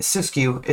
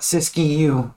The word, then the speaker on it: Siskiyou: Yes, although, it's Siskiyou. I'm going to give that Siskiyou. It's not Siskiyou. We don't have to put that extra syllable in there.